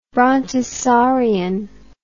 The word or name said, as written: brontosaurian